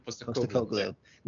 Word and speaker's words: Postecoglou: Postecoglou, that's why. I don't know if I'm saying his name. – Postecoglou. No, that's correct.